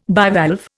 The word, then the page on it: bivalve: Indian